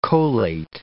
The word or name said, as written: cholate